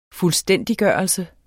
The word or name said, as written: fuldstændiggørelse